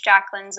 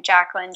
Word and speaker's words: Jaclyn: You can either friend me on my personal page or you can follow me on my professional page, Jaclyn Zavaro Intuitive Guide. And I'll just spell my last name for you guys. It's spelled Zavaro. And my first name, Jaclyn, Jaclyn.